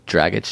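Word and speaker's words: Dragić: A-G-I-C, but it's pronounced so differently. Dragic.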